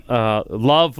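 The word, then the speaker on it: LOVB: Love with the silent B.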